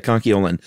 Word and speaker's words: conchiolin: Conchiolian. – Yeah, you're throwing that L in there. – Mm. – It's very easy to want to do. – Conchiolian. – I get the urge. Yeah, conchiolin. And nacre, by the way, is spelled N-A-C-R-E.